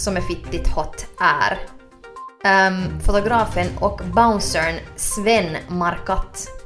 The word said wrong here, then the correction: Marquardt: Jag är inte helt säker på om man uttalar hans namn så. Mar Marquat det korrekta uttalet är Marquardt.